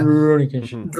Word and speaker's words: Rurouni Kenshin: Rurouni Kenshin.